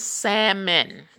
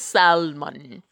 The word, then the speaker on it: salmon: Y'all, it's salmon. There's no such thing as salmon. Okay, the L is silent.